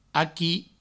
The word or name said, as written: aqui